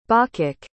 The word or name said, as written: bawcock